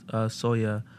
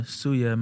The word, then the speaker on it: Suya: So, there's this thing called Soya, or Suya might be more common pronunciation for, like, people who are not Cameroonian.